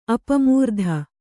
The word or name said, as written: ಅಪಮೃತಿ